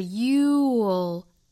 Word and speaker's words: you'll: What you can do, if you only do what you can do, 那这个 can 我们做重读，后面 you'll never, you 和 will 的一个缩约读法呢是 you'll, 也很简单，也就是 you 发完整之后我们去找一个舌侧音就可以了。 you'll, 如果不缩约就是读成 you will,